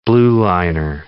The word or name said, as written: blueliner